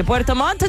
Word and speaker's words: Puerto Montt: Hay que pronunciar bien, porque Puerto Montt se escribe con dos T.